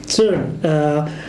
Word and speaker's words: CERN: CERN. Das ist eine französische Abkürzung, die irgendwie komischerweise immer englisch ausgesprochen wird.